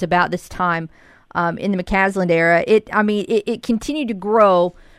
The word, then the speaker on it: McCasland: Am I saying his name right, McCaslin? – McCasland, yes. And you know, it was kind of one of those things where the momentum grew throughout the year.